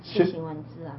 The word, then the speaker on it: 楔形文字: 那既然是这样的话，他说我们在谈的是一个生物上的楔形文，那叫楔形文字，这怎么念呢？ – 是啊，楔形文字啊。